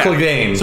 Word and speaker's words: Clegane: Clegane, Sir Gregor Clegane. There we yeah. go. Some people say Clagon.